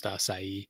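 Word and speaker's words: açaí: At the risk of sounding pedantic, just to save you from future embarrassment, that fruit is pronounced acai. – Acai. – Yeah. Just want to make sure we get that right for, I believe that's like a Portuguese word.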